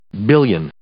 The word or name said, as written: billion